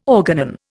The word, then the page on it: organon: Indian